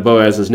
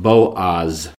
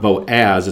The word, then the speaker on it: Boaz: That name, by the way, it's pronounced Boaz. If you remember any of you Hebrew students, an A sound in Hebrew is usually ah, as in father. So it's not Boaz, it's Boaz.